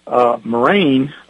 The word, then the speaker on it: M'Rain: Folks, when you get to this book, Moraine is spelled M'RAIN.